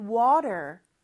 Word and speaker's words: water: 특히 미국에서는 저 water의 T 발음이 리을처럼 짧게 발음이 되죠. 그래서 water라고 하지 않고 water 이렇게 발음을 하는 거고요.